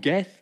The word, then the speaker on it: Gath: Ich habe es extra gecheckt, wird wirklich so ausgesprochen, Gath.